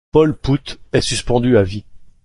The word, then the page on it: suspendu: french